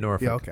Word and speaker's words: Norfolk: And then Norfolk. – Yeah, okay. – 1-2 punch. – Yeah. – And see, I said it correctly. – You did. – Most people don't.